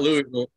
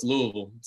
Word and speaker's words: Louisville: It's not Louisville. It's Louisville. So, yeah.